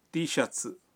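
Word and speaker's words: تی شتس (Tシャツ): T shirt کا جاپانی تلفظ ہے تی شتس۔ نینجا ٹی شرٹ کہنے کے لیے حرف جار نو لگا کر یوں کہیں گے نینجا نو تی شتس، نینجا نو تی شتس۔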